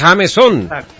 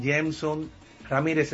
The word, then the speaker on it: Jameson: Sí, exacto, el nombre que me dio mi madre realmente se pronuncia en inglés, Jameson Ramírez, se escribe Jameson, uh-huh, con J. Jameson.